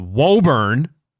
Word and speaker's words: Woburn: Remember, if you're from outside of Massachusetts, even though it's phonetically spelled Woburn, it is pronounced Woburn.